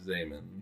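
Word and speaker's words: Zeeman: And is it Zaman? – Yeah. – You got the pronunciation? – Yeah, he's Dutch. – Oh, okay. Because it's spelled Z-man. – Yeah. It is spelled Zeman, but it is Zaman.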